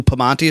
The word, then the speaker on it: Pamonti: I think it's Lou Pamonti.